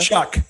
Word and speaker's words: Shough: Chuck. It's Chuck. Chuck. Yeah. It's not spelt like that. It's like S H O U G H and it's pronounced Chuck.